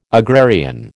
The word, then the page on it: agrarian: American English